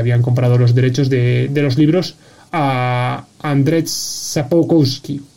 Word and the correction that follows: Sapkowski: Pero a lo mejor tú lo pronuncias mucho mejor, Víctor. – Eh, estaba, estaba con eso porque no sé exactamente cómo se pronuncia. Es Andrzej Sapkowski.